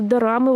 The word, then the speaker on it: дорама: Это на самом деле вот как драма, только из-за корейского произношения а, получается, что они называются дорамы, и какой-то прям целая культура это получается, отдельная корейские дорамы.